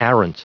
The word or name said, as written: arrant